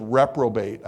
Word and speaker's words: reprobate: And then it says reprobate. I was looking up retrobate, the definition of it. Reprobate. I'm sorry. I think I said it wrong.